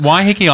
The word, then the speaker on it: Waiheke: Well, now on Mind Food Radio, we're off to a place called Waiheke Island. Where on earth is that? – You Aussies, I love the way you try and pronounce that. Waiheke Island is just off the coast of Auckland.